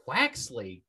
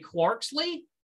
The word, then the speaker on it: Quaxly: Quaxly. Excellent pronunciation. I was going to say Quarksly, and clearly that's wrong because there's no R.